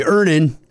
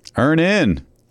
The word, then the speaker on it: Earnin: Earnin. – Earnin. – E-A-R-N-I-N is pronounced Earnin. I don't know how else you would pronounce it, but they really want it clear that that's how to pronounce it.